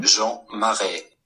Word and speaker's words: Jean Marais: Jean Marais.